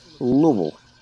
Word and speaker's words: Louisville: Like, you just have, it just has to mumble out of your mouth, like one syllable, just like Louisville.